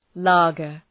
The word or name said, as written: lager